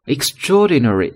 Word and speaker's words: extraordinary: Extraordinary 是我们看的第一个形容词，注意它的读音呢是 extraordinary，extraordinary 比较长的一个词，但是呢一定要读清楚，每一个音节都读出来。